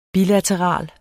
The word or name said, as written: bilateral